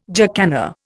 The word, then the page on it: jacana: Indian